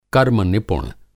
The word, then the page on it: कर्मनिपुण: Hindi